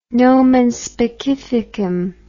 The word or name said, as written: nomen specificum